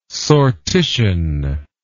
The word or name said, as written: sortition